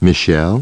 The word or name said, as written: Michelle